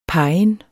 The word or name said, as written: pegen